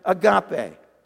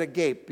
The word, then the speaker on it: agape: One is the Greek word agape. Now, don't pronounce that agape because that's just confusing. Agape.